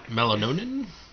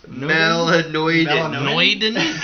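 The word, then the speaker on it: melanoidin: Melanonin? – Melanoiden.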